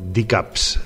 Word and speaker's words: DKVPZ: Este duo que ven a continuació es diuen D-Cups, així és com es pronuncia, en realitat el seu nom és D-K-V-P-Z.